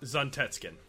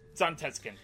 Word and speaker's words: Zantetsuken: Zantetsuken. – And when he finally does, he's just like, yeah, forgive me, Zantetsuken. Yeah. Or Zunket. Whatever it was called. – Zantetsuken. – Zantetsuken, thank you.